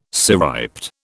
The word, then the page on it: cirriped: British English